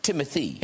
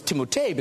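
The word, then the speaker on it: Timothée: It's pronounced Timothy. – It's whatever you'd like. – I thought it was Timotei because of the double E's at the end.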